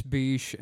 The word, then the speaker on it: Bysshe: Now, and I believe it's pronounced Beesh. I don't, you know, it's... – Let's say it's Beesh and move on.